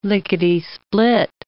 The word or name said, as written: lickety-split